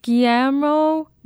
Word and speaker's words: Guillermo: Guillermo del Toro.